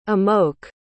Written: amoke

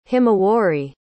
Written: himawari

